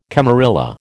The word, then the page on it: camarilla: American English